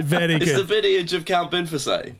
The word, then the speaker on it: Binface: Binface is actually pronounced Binfase.